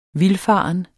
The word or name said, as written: vildfaren